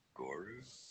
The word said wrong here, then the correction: Grogu: Grogu. – Grogu. Ah, yeah. – Damn, dude. – Was close, not quite though.